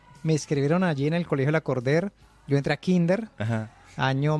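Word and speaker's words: Lacordaire: Es que es un nombre francés, y se escribe La Cordaire, pero se pronuncia La Cordère.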